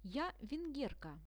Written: ja vengerka

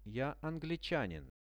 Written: ja anglichanin

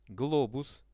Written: globus

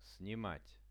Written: snimat

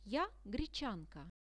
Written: ja grechanka

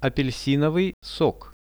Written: apelsinovyj sok

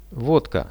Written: vodka

